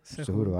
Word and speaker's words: Cejudo: Pleasure to be here today with my boy, Triple C, Henry Cejudo. Cejudo, no? – Yeah. – Cejudo. I didn't want to say it wrong. Cejudo.